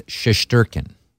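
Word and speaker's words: Shesterkin: Because in the NHL pronunciation guide, it's Shestyorkin. And that's what John Forslund was calling him on Tuesday night. – A shout by Comfort denied by Shestyorkin. MacKinnon winds it in. Kadri, good play. Landeskog. Shestyorkin, what a save there.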